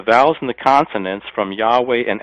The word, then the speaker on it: Yahweh: Most scholars believe that the proper way to translate that is Yahweh, or to pronounce it, Yahweh.